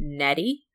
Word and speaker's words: Nnedi: Hint to you, the author's name is pronounced Nnedi.